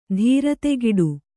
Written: ಧೀರತೆಗಿಡು

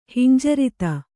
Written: ಹಿಂಜರಿತ

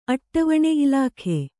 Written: ಅಟ್ಟವಣೆ ಇಲಾಖೆ

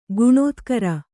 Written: ಖಣತ್ಕಾರ

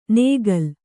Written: ನೇಗಲ್

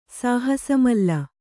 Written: ಸಾಹಸಮಲ್ಲ

